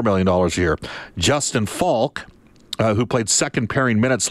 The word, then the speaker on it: Falk: and that's the Falk that's spelled F-A-L-K, not the Falk that is spelled F-A-U-L-K of the Carolina Hurricanes,